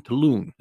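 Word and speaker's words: Taillon: You know, it's spelled different in the way it sounds. It's one of those things I had to look up the pronunciation to get it right, actually. Jameson Taloon is the way it's pronounced.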